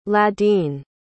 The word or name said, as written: ladin